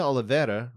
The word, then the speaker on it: Oliveira: Diana Babitza, rhymes with pizza, got the win over Maria Oliveira, rhymes with era.